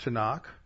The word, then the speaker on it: Tanakh: And so the T-N-K is pronounced Tanakh, and that's their scripture.